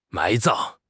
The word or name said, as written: 埋葬